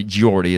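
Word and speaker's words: Geordie: I always called it Gordy, but it's actually Giordi is how it's pronounced. It's pronounced the same way it's spelled.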